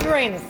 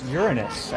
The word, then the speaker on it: Uranus: You're playing with us at Uranus. – Playing with I believe it's pronounced Uranus, actually.